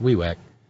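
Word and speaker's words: Vivek: It's properly pronounced WeWeck, but asshole is the same in any language.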